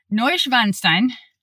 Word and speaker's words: Neuschwanstein: We are in Germany about to visit Neuschwanstein.